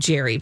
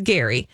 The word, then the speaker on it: Gerry: But it's spelled G-E-R-R-Y, oh. So sometimes people accidentally call him Jerry, but okay. No, he's Gary.